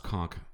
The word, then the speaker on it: conch: It's not. It's conch. It's fucking stupid. I hate it when people say conch.